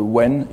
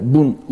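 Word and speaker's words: wen (文): c'est-à-dire le wen ici, donc qu'on peut prononcer en japonais bun ou mon,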